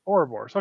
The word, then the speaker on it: Ouroboros: Ouroboros. Yeah. – Ouroboros. Okay, I said it right this time.